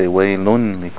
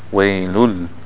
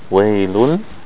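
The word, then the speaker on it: waylul (وَيْلٌ لِّ): See, the funny thing is, in the first one you pronounce it, you didn't say waylun li kulli. You did the idgham, right? Waylul. Right? The tanween, waylul.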